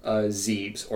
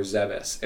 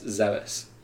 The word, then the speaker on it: Zebes: Somebody needs to actually this, because I think she's actually going to Zeebs, or Zebes, if you want to pronounce it correctly. But I've always said Zeebs. But it wasn't until Other M that I had heard it actually pronounced as Zebes.